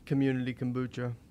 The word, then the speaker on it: Community Kombucha: It's, how do I say this? – Community Kombucha.